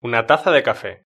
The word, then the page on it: una taza de cafe: European Spanish